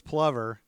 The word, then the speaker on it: plover: It's plover, like okay. Danny Glover.